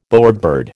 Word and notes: bowerbird: American English

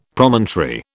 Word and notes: Promontory: British English

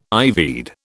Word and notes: ivied: British English

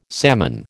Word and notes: salmon: American English

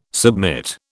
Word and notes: submit: British English

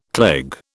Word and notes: cleg: British English

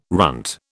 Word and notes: runt: British English

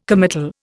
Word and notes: committal: Indian

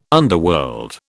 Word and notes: underworld: British English

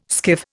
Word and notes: skiff: Indian

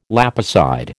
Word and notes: lapicide: American English

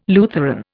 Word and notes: Lutheran: Indian

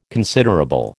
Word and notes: considerable: American English

